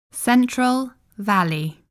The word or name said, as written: Central Valley